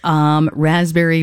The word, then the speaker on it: raspberry: Or if you eat a raspberry, you're like, it's just like, oh, silent P.